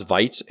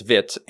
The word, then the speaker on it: Vite: So they have Vite, which I used to pronounce Vite, and I still really want to pronounce Vite, to be honest, but apparently the Vite creator, which is the same creator as Vue, says it's Vite, and that's fine, whatever.